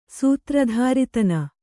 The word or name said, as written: ಸೂತ್ರಧಾರಿತನ